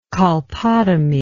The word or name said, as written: colpotomy